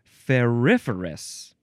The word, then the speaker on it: ferriferous: Ferriferous? Is that how you pronounce that word? Yeah, it's coming up in this word. It's ferriferous. Ferriferous, that's how you say it.